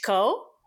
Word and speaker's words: Shiko: It's Shiko. – Well, so obviously you have the two syllables, right? You have Shi yeah. and you have Ko. And you can say Shiko or you can say Shiko, which is really just all I'm doing is truncating the I there.